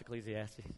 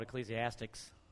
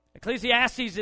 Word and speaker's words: Ecclesiastes: Some of us don't even know how to pronounce Ecclesiastes. I used to call it Ecclesiastics. Ecclesiastes is, is written by Solomon, and it's basically written by a man who has everything.